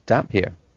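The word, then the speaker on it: Damphair: Yeah, I was reading the Facebook group, and people were discussing how it's pronounced damp fair. Like, whatever. – Oh, Jesus. – That's what I thought at first, and then I'm like, oh, it's damp hair.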